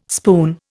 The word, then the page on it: spoon: Indian